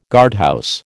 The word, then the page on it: guardhouse: American English